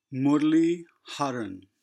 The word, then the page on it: Murali: "u" is pronounced as in the first "u" in "aluminum"; the "a" leads to a quick "uh" sound